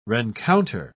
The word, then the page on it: rencounter: British English